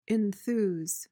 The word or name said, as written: enthuse